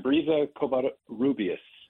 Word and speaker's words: Brisa Covarrubias: Yeah, Brisa Covarrubias. You can roll the R if you want.